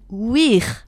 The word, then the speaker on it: vir: Alors, l'homme romain, le vrai, c'est le vir ou wir. – Hein. Wir, avec la prononciation latine.